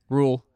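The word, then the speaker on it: Rhule: The head coach that the Carolina Panthers just hired, Coach Matt, how do you say his last name? – Rule. – Matt Rule.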